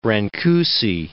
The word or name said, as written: Brancusi